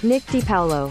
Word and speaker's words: Nick DiPaolo: Nick DiPaolo.